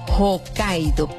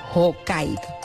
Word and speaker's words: Hokkaido: Sí, búscala, porque se llama Hokkaido, pero se escribe H-O-K-K-A-I latina D-O, Hokkaido.